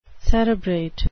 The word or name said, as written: cerebrate